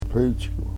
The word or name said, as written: pechku